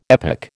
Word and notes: epoch: American English

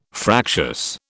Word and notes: fractious: British English

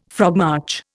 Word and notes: frogmarch: Indian

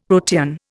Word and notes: protean: Indian

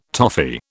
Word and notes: toffee: British English

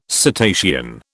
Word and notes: cetacean: British English

